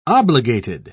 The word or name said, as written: obligated